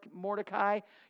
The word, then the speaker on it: Mordecai: And I know some of you want to say it Mordecai. You just say it however you want. It's pronounced Mordecai.